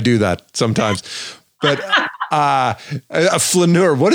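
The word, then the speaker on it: flâneur: A flaneur. Am I saying that right? – You are.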